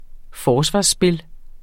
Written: forsvarsspil

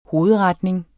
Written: hovedretning

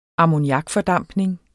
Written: ammoniakfordampning

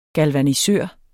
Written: galvanisere

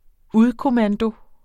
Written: udkommando

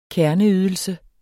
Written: kerneydelse

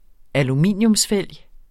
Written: aluminium

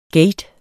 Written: gate

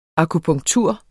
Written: akupunktur

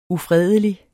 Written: ufredelig

